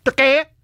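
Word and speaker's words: Türkiye: Turkey! I believe is how that is to be pronounced.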